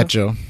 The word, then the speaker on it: hecho: Echo.